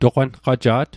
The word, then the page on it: toqanqaja't: Mi'gmaq